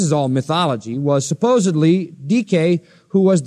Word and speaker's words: Dike: Justice is the name of a goddess, Dike, D-I-K, long E.